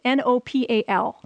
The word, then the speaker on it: Nopal: How do you spell that? – It's Nopal. – Okay, it's not the country. – No, it's not. – Nopal.